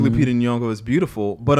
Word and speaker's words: Nyong'o: Is that how you said Nyong'o? – Yeah. – What about her? – Soft G Nyong'o. – Oh, thank you. Do it again. – Nyong'o.